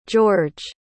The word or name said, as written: george